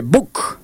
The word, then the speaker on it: Buch: Pero antes, claro, sobre Libertad, tenemos a su técnico, a Juan Pablo Buc. Se escribe Buch, pero es Buc, como lo pronuncian.